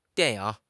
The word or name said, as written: dian4 ying3